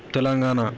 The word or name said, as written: Telangana